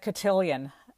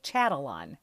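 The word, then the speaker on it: Chatillon: I used to think it said cotillion, I mean, this is how quickly you look at something, and I always love to know the exact pronunciation of something. So the dealer I bought it from corrected me, lovingly corrected me, and now I've learned to say it correctly, Chatalan.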